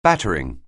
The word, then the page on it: battering: anglais